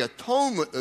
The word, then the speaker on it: atonement: And a good way to remember what the atonement is to pronounce it this way, at-one-ment.